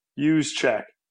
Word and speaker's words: Juszczyk: It's one of the silent J's. Use check.